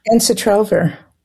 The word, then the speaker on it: Ensitrelvir: Ensitrelvir. – Ensitrelvir.